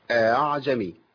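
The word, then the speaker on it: أأعجمي: يبقى بسهل الهمزه بين الهمزه واصل حركتها. واحنا عندنا ثلاث كلمات ثانيه يضافوا للكلمه ديت، لينا فيهم وجه تسهيل في بعض طرق حفص، اللي هم الله الان الذكرين. خلاص، يبقى نطقها الصحيح ايه؟ اعجمي اعجمي اعجمي.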